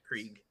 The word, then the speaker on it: Krieg: We have Gage, and I think it's Krieg. Is that how you pronounce the band? – Yes. Krieg.